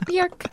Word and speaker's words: Björk: It's like Björk. – Yeah. – It rhymes with work, like.